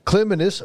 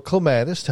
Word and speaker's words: clematis: Now, what clematis or clematis, however you prefer to pronounce it, I think both are recognized as being correct, is that they like a sunny location, but they like cool feet.